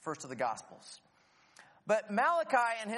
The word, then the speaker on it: Malachi: Now, forget the whole Italian prophet thing, because all of the prophets are Jewish Israelites, and it's pronounced Malachi.